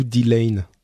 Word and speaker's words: Delain: Pardon, le deuxième, c'est D-Lay ou D-Lane ? – D-Lane, ça s'écrit D-E-L-A-I-N.